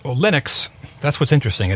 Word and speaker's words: Linux: Linux came out in August 25th of 1991, again about 4 years before Windows 95.